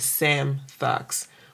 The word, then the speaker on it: Svmtox: Und das V ist eigentlich, man setzt als A aussprechen. Deswegen Sam Thugs.